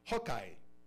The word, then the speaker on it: Hawkeye: Hubo Hockey. A ver, déjame se lo pronuncio bien. En realidad es Hawkeye, ojo de halcón.